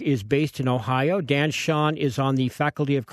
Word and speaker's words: Chaon: But right now, I want to present an interview I taped about an hour ago with a wonderful writer called Dan Shaun. That's spelled C-H-A-O-N, if you're reading his books and want to know how to pronounce his name, which I did.